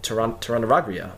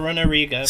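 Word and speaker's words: Torunarigha: Turunariga. Apologize if I'm mispronouncing his name, but just to recap, obviously. – Turunariga. – Sorry, it's a difficult one.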